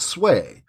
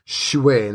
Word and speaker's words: Schuett: And his name is Chip Shuey, I think. Not Sway. Shuey. And it's spelled S-C-H-U-E-T-T.